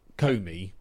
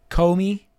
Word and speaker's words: Comey: I think it's pronounced Comey, but Comey. I like your way of saying it as Comey.